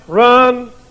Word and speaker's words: ruin: Spell R-U-I-N. Depending on what part of the country you're from, how you pronounce that. And the word ruin, it's pronounced run. Yeah, I've heard country people say, that'll run you.